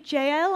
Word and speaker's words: Jael: And finally, there's a surprise side character with an unusual name, Jael, I think I would pronounce it, J-A-E-L. I must tell you that when we lived in Guatemala, we actually knew a couple who called their daughter this, but the J is H.